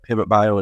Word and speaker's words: Pivot Bio: Is it just now that we have the technology to be able to, in a sense, harness that and do what Pivot Bio is doing? Is that the right way to say it? – Yeah, yep, I think that's right.